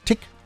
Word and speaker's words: Tich: Dave die Dozy, Breaky Mick und Tick. Hideaway. Ich muss immer aufpassen, dass ich das ordentlich ausspreche. Ne? T-I-C-H wird Tick ausgesprochen. Ja, so wie Tick-Tack.